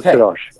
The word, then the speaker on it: Sirosh: Sirosh, am I pronouncing that right? – Yes, Joseph Sirosh.